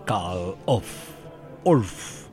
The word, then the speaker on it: Karl Orff: Y programamos esa música aquí en el Me Haces Tanto Bien, porque un día 10 de julio nació el gran Karl Orff, ahí lo pronuncié bien.